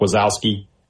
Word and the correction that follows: Wazikowski: at Oregon. – Wazikowski, yeah. – Wazikowski, thank you.